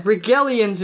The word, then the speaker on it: Rigelians: Are you sure it's a soft G? – Yeah, Regellians is how I... – It's soft G before E.